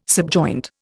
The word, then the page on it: subjoint: Indian